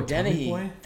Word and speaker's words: Dennehy: Brian Deeney? Am I saying that right? – Oh, Dennehy.